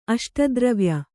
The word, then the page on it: ಅಷ್ಟದ್ರವ್ಯ: aṣtadravya